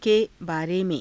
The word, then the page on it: ke baare me^: Hindi